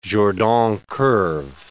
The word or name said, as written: Jordan curve